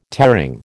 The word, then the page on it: tearing: American English